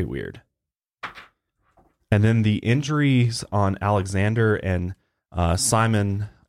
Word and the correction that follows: Semyon: I think is how you say it, were even stranger. – Semyon.